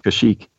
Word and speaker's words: Kashyyyk: What is the Wookiee's homeworld, and how do you say the name? – Yeehaw. – Go ahead. – Kashyyyk. – Very good.